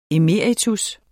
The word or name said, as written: emeritus